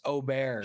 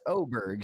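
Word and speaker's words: Åberg: But they put a little inflection on the back. It's like Aberg, like Aberg. I can't even say it correctly, but they don't say Aberg. Just Aberg. Yeah, exactly. They throw a little roll on the end of it for you.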